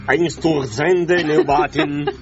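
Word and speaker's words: Einstürzende Neubauten: Che si chiamano Einsturzende Neubauten.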